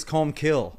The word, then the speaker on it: Colmcille: His name is Comb Kill. – Yeah. – Yeah. but yeah, I just learned today that's how his name is pronounced because uh, I wasn't born here or in Ireland, so I don't know any of this stuff.